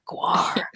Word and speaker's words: GWAR: The band GWAR, do you just say it like the letters like that? Or Gwar.